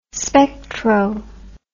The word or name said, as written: spectro